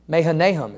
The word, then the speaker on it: Mahanaim: Mahanaim is how you say that.